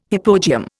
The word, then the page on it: hypogeum: Indian